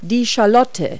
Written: shallot

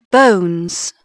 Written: bones

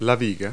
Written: girder

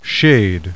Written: shade

